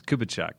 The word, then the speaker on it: Kubitschek: And the quick story, my last name is kind of hard. Lots of people butcher it, but it's Kubitschek, right? – Kubitschek.